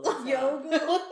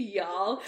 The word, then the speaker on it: Youghal: Yogel. – What? – Y'all. You know. – The town y'all. – How do you spell it? – Y-O-U-G-H-A-L. – Alright, fair enough. Why? I thought it was Y-A-L-L and you were like, no, there's a G-H.